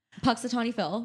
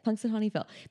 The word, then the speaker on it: Punxsutawney: groundhog, Punxsutawney Phil, which we talked about yesterday.